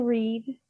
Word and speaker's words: Reed: Yeah, it's Keena Reed. – Okay, R as in Roger, E as in Echo, E as Echo, and D as in Dog.